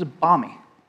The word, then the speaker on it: balmy: They also, you're not supposed to pronounce the L. So this is balmy.